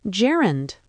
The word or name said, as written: gerund